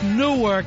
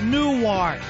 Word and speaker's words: Newark: It's pronounced Newark, not Newark.